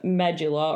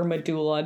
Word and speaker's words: medulla: And then the innermost layer or the core of the hair is the medulla or medulla, depending how you pronounce it, fellow nerds.